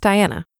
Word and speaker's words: Diania: Yes, I have an extra I in my name. It's spelled D-I-A-N-I-A. But it's pronounced Diana. I will say that I do find it a bit ironic that there's a silent I in the name of someone who literally never shuts up.